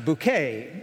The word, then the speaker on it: Bucket: Well, Hyacinthe's last name is actually spelled B-U-C-K-E-T, Bucket, but she wants it pronounced Bouquet, like Target and Target.